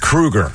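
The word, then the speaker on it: Kroeger: But Chad Kroger from Nickelback has done that too. – Yeah, it's pronounced Kruger.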